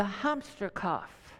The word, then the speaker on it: Hamsterkauf: And the word is, and I've never taken German, so I apologize if I pronounce this wrong, it's Hamsterkauf, Hamsterkauf, Hamsterkauf.